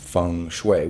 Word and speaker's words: feng shui: Now we're talking about the ancient art, science, and religious system of feng shui, which the cognoscenti know that it's feng shui, even though it's spelled feng shui. So if you're looking this up on your Google, it's F-E-N-G-S-H-U-I. But for some reason in Chinese, wind and water is feng shui.